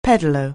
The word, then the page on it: pedalo: anglais